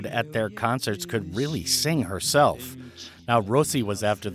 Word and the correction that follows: Rosi: that, and actually, I mispronounced that. That's Rossi. It's spelled R-O-S-I.